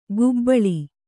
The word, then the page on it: ಗುಬ್ಬಳಿ: gubbaḷi